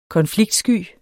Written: konfliktsky

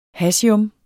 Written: hassium

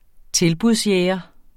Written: tilbudsjæger

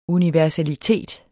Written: universalitet

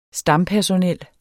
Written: stampersonel

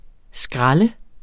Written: skrald

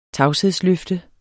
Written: tavshedsløfte

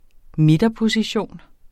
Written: midterposition

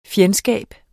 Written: FJENDSKABERNES

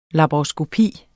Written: laparoskopi

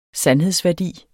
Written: sandhedsværdi

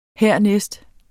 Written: hernæst

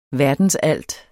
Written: VERDENSALTS

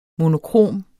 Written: monokrom

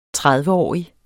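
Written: tredveårig

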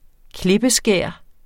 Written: klippeskær

